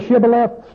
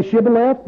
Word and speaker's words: Shibboleth: When the kingdom was being taken, can you say Shibboleth? They say, yeah, Shibboleth. Nope, cut your throat, throw them out back. See, that's what they did to them. They killed them because they couldn't say, pronounce that H.